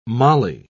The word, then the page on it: molly: British English